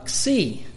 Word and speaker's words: xi: xi, which is KSI, which is a KS sound,